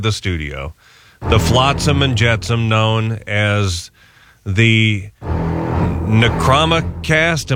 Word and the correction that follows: Necronomicast: Am I saying that correctly? Necromacast? – You butchered it. – What? Necronomicast. – There you go. – Is that it? – That's it. Necronomicast. – I got to put an extra syllable in there.